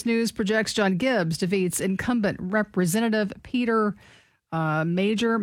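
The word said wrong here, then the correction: Meijer: I'm not sure. – It's pronounced Meyer.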